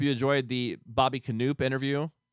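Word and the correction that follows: Knoop: Knopp, I should say. My gosh, I just said his name wrong.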